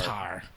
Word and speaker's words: Qatar: in Qatar, Qatar. Qatar. And by the way, it's pronounced both ways.